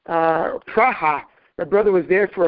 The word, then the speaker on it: Praha: And he says to me, you know, they pronounce it Praha over there.